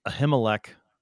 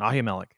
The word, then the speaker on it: Ahimelech: And so because of who he is, and Ahimelech, I think is how you said it. We'll try to use your pronunciation. Ahimelech, right? – Whatever.